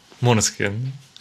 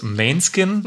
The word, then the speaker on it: Måneskin: Ich spreche es meistens so englisch falsch aus. So Manneskin sage ich immer. Deswegen noch einmal richtig.